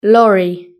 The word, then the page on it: lorry: British English